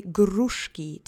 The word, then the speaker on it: gruszki: Now that you have that fresh on your tongue, let's say gruszki together three times. Accent is on the first syllable. Try to roll the R if you can, but don't sweat it if you can't.